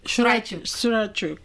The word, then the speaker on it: Shurachuk: And your brand, is it Shurachuk? – Shurachuk. So it actually means leatherback turtle in Arapesh, which is my father's language from Waltagik Isipik. – Dagua. – Yes. – Wow, so pronounce it again.